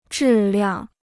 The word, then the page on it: 质量: zhì liàng